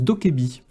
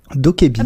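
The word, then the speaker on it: DokeV: On nous dit sur le chat que ça se prononce Dokebi. – Dokebi.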